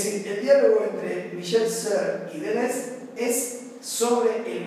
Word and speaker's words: Serres: El diálogo que establecí es entre Michel Serre, se escribe Serres, Michel Serre, y Gilles Deleuze.